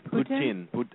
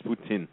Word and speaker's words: poutine: Poutine. – Apparently, they're telling me it's poutine. But I said poutine because it's I-N-E.